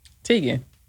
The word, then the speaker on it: Teigen: Teigen, is it Teigen or Teigen? – Teigen. That's how they say it on Wild N' Out.